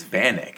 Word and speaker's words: Vanek: Why are you pronouncing his name so weird? It's Vanek. It's Vanek. I'm pretty sure I speak English. It's Vanek. Uh, it's a Slovak it's name, but he's uh, from Zell am See, uh, Austria.